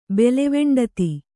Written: ಬೆಲೆವೆಂಡತಿ

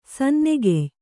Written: ಸನ್ನೆಗೆಯ್

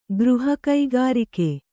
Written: ಗೃಹಕೈಗಾರಿಕೆ

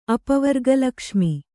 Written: ಅಪವರ್ಗಲಕ್ಷ್ಮಿ

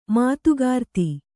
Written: ಮಾತುಗಾರ್ತಿ

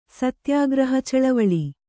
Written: ಸತ್ಯಾಗ್ರಹಚಳವಳಿ

